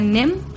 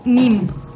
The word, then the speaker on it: neem: N-E-E-M Aquí lo podemos pronunciar NEM, eh, me parece que se pronuncia en hindú NIM.